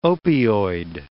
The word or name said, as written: opioid